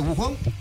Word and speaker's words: Wuhan: El muñeco Gallardo se encuentra con toda la selección ya hospedada en un hotel céntrico de la ciudad de Wuhan, ¿así es el nombre? – Así es.